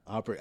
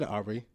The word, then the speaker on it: Aubrey: Aubrey. – I said Aubrey, like Aubrey Graham, like Drake. Aubrey. – You gave an Audrey the second time, like...